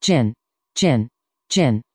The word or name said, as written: gin